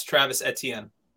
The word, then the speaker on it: Etienne: I need you to pronounce. – That's Travis Etienne. – Okay, so Travis Etienne.